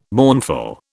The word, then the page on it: mournful: British English